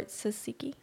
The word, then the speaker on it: tzatziki: Tzatziki or tzatziki? – Yeah.